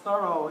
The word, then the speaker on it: Thoreau: So Thoreau here, pronounced Thoreau in New England, where I grew up, Thoreau said we become the tools of our tools.